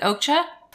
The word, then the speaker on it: Okja: Is it Okja? O-K-J-A? – Yeah.